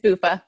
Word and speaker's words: Buffa: Is it Buffa? Buffa? – It is. It's Buffa.